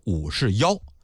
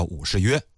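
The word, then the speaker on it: 武士彟: 当然这个字儿啊它也念获，有学者考证过，说按照唐音来念呢应该念成武士妖，可是通俗来讲呢史学界都管他叫武士约。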